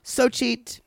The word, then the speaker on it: Xochitl: X O C H I T L, which is pronounced, I believe, Xochitl.